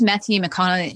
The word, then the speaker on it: Matthew McConaughey: I think I loved Matthew McConaughey.